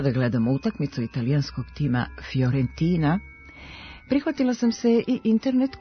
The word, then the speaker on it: Fiorentina: Ovo pravilo važi i za predvokalsko fi, koje se čita i piše kao fj, Fiorentina, a ne Fiorentina.